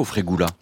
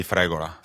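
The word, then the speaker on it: fregola: Mais vous, à titre personnel, vous dites frégola ou frégula? – Moi, je dis frégola.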